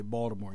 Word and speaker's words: Baltimore: You do say Baltimore correctly. You make the T. – Why? What? Why? How do people say it? – Baltimore with a hard T, but you say it correctly. Baltimore.